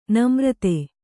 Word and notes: ನಾಮರ್ದ: nāmarda

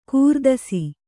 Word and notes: ಕೀರ್ತಿಸು: Kannada

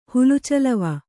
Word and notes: ಹುಲುಚಲವ: Kannada

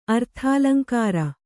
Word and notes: ಅರ್ಥಾಲಂಕಾರ: Kannada

